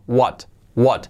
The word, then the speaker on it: What: What, la pronunciación que no sea gua, gua, gua, gua, gua, gua, gua, ¿no? Cuidado, la W, gua, gua, what, what.